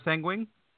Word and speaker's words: Seguin: S-E-G-U-I-N.